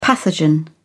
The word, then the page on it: pathogen: British English